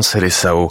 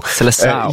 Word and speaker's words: Seleção: Vous prononcez bien Seleção. – Seleção.